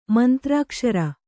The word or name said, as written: ಮಂತ್ರಾಕ್ಷರ